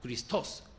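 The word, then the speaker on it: ク リ スト ス (Χριστός): ちょっとヘブライ語っぽく発音すると、メシアはメシアフ、メシアフで、ギリシャ語でキリストはクリストス、クリストス、全く発音違いますけれども、意味はいずれも同じ意味で、油注がれた者、特別の使命を帯びた者、救い主を意味しました。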